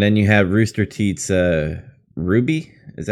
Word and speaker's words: RWBY: R-W-B-Y? – I guess that's how it's pronounced. I used to say the letters, and then when I worked at FYE, someone corrected me. So I think that's right.